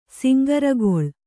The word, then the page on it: ಸಿಂಗರಗೊಳು: singaragoḷu